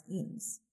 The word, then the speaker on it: Jens: and his first name is spelled J-E-N-S, but I guess it's pronounced Eames.